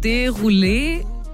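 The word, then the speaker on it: Desrouleaux: Und sein Nachname wird, ich glaube, Derulé ausgesprochen. Derulé? Also geschrieben wird Desroleux.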